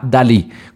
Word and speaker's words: DALL-E: ChatGPT es solamente pues texto, entonces no funciona, pero la empresa que está desarrollando ChatGPT tiene otra plataforma que se llama Dalí, como Salvador Dalí, el famoso artista español, pero se escribe D A L L E, Dalí.